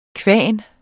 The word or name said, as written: kvan